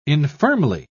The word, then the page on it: infirmly: British English